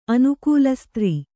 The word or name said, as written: ಅನುಕೂಲಸ್ತ್ರೀ